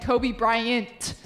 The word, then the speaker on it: Kobe Bryant: I have a friend who literally always says Kobe Bryant, does not pronounce the T. And I tell him, like, dude, there's a T at the end, Kobe Bryant.